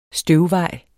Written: støvvej

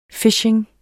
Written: phishing

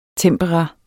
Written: tempera

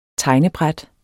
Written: tegnebræt

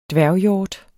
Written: dværghjort

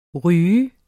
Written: ryger

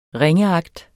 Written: ringeagt

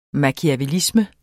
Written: machiavellisme

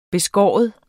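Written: beskære

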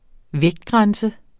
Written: vægtgrænse

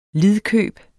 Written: lidkøb

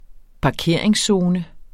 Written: parkeringszone

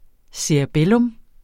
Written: cerebellum

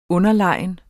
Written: underlegen

